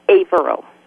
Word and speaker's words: Averill: Is it Averill? – Averill. – Averill. – Long A.